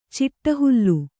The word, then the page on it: ಚಿಟ್ಟಹುಲ್ಲು: ciṭṭa hullu